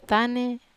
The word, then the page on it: tane: Wamesa